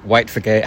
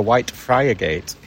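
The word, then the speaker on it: Whitefriargate: When you see it written down and visitors come and pronounce it correctly, they say we're looking for White Friar Gate. – That's right, yeah. But in Hull, it's White Frigate.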